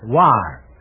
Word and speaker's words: Loire: I'm sorry, the correct answer is L-O-I-R-E, pronounced war.